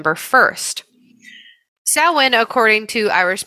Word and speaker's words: Samhain: They call him Sam for Samhain, but it's Samhain.